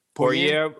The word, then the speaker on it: Poirier: Poirier? – Poirier.